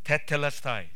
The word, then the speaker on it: tetelestai: Let me try to pronounce it. I'm not a Greek scholar. Tetelestai. Tetelestai means it is finished.